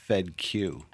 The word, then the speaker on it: Fedkiw: It's like FedEx, but it's pronounced FedQ.